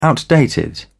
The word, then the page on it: outdated: British English